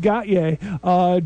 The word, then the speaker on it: Gotye: I'm not sure how exactly even to pronounce their name, but I believe it's pronounced Gotye, G-O-T-Y-E, and the song is somebody that I used to know.